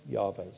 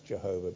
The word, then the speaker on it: Yahweh: One of the names that God gave himself was the name Yahweh, sometimes pronounced Jehovah, but the correct way of pronouncing it is Yahweh.